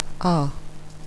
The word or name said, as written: ah